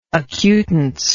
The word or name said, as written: acutance